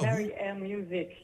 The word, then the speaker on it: Mary Ann: Maar je hebt ook je muziekstudio Mary Ann, als ik het zo moet uitspreken, Music geopend in Epe. – Ja. – Vertel. – Mary Ann Music. Ja, dat klopt.